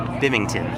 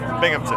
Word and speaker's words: Binghamton: Hey, this is Tim Nordwin from the band OK Go, and you're listening to WHRW Bimington. Is that right? – Binghamton.